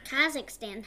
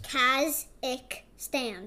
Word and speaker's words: Kazakhstan: Kazakhstan. How do you pronounce that? – Like this. – Kazakhstan. – You got it.